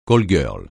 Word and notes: call-girl: francais

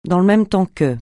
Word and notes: dans le même temps que: francais

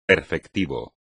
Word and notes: perfectivo: espagnol

